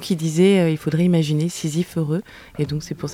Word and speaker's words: Sisyphe: Oui, est-ce que je le prononce bien ? – C'est ça, Sisyphe.